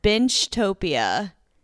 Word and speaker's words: Binchtopia: It is called Binchtopia. – Okay. – So I've never heard of that. – It's spelled B-I-N-C-H and then Topia.